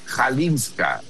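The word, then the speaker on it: Chwalińska: Ya investigué cómo se pronuncia este apellido, mi querido Pascal, amigos, porque es un apellido que es CHW y eso en polaco se pronuncia J, es lo que acabo de indagar. Entonces es Kalinska.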